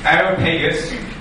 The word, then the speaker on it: Areopagus: And in verse 22 says, Then Paul stood in the midst of Areopagus,